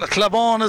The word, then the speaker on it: Cloughbawn: Cloughbawn is the proper pronunciation down this neck of the woods, as Thomastown come forward looking for the first score of the second half.